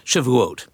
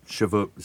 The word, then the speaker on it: Shavuot: You mentioned, and I'm going to mispronounce it, so get ready to correct me. You can't have Passover without Orem. – Shavuot. – Shavuot. See, not even close.